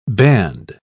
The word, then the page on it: banned: British English